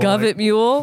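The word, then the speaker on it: Gov't Mule: Like, is it Government Mule or is it Govet Mule? – Govet Mule. Like, Guft Mule. – Yeah. – Like Govt Mule.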